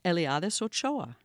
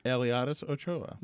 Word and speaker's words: Eliades Ochoa: Okay, now this I have a pronunciation, but I screwed up the last one, so I'm going to try my best. Eliotis? – Eliades Ochoa. – Eliades Ochoa.